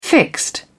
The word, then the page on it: fixed: British English